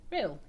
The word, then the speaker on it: Rhyl: There's a place called Rhyl. – Rhyl. – Is that how you say it? – Rhyl, yeah.